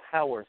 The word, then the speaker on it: Howarth: And it's pronounced Howarth. – Howarth. Oh, H-O-W.